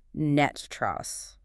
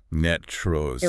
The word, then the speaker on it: NTREIS: And if you are reading that acronym, it's NTREIS. – Right. – But locally, it is universally pronounced NETTRES. – NETTRES. – It rhymes with rust.